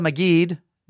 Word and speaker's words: Magid: Finally, from Linda Magid. No, she says pronounced like magic, but with a hard G.